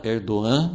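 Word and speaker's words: Erdoğan: Erdogan, né? A gente geralmente fala com G, né? Como pronuncia em português.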